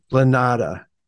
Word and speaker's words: Lanata: Lanata.